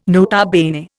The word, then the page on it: nota bene: Indian